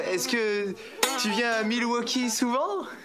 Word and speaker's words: Milwaukee: Ça se prononce Milwaukee.